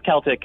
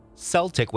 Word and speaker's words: Celtic: For years, English speakers pronounced the word Celtic with a soft C. – Yeah. – It's how people pronounce it in the United Kingdom.